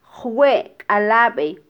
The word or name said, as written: jwekalabe